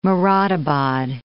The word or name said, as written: Moradabad